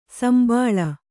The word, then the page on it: ಸುಂಬುಳ: kannada